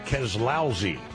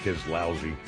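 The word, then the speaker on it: Keselowski: It is pronounced Keslousy. Emphasis on lousy. Signed, Denny, number 11. Okay, Keslousy.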